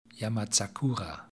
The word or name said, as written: yamazakura